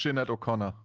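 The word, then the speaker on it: Sinéad: Musste tatsächlich gucken, wie man das ausspricht. Und ich habe lustigerweise auch Aussprachen gefunden, die das genauso aussprechen, wie man schreibt. Also Sinead. Da habe ich mir gedacht, ich glaube, das ist falsch. – Ich kenne es nur als Sinead O'Connor. – Ja, ich habe dann tatsächlich eine irische Aussprache gefunden. Das ist Sinead eigentlich, aber Sinead ist auch okay.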